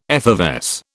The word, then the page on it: effervesce: British English